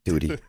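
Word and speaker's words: Duddy: Duty. Rhymes with Judy.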